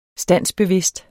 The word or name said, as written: standsbevidst